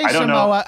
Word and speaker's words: Samoa: Samoa! So Samoa? Do I say it? I say it wrong. – I say Samoa. I think a lot of white people say, or non-Samoans say Samoa.